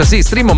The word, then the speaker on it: Streammo: che si pronuncia si pronuncia Streamo, ma si scrive Streammo.